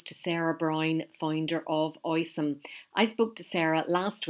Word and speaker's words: Oysome: Sarah, lovely to meet you, and you're joining us this evening to talk about Oysome.